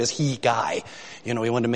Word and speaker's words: Hegai: Side note, by the way, I wanted to make sure I knew how to pronounce that name, Hegai. It's Hegai, and he's a eunuch.